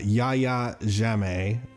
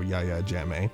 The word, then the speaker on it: Yahya Jammeh: But former Gambian president, I'm going to totally butcher this name, Yahya Jammeh, Y-A-H-Y-A, so Yahya Jammeh,